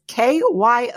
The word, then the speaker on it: Kyowa: So we snagged an interview with Danielle Citrullo, Doctor of Pharmacy and Technical Services Manager for Kiowa Hako. I think I'm pronouncing it right. It's Kyowa, new word, Hakko.